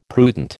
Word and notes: prudent: American English

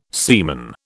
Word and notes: seaman: British English

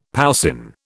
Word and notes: poussin: British English

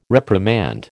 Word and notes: reprimand: American English

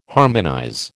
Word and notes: harmonize: American English

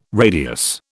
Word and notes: radius: British English

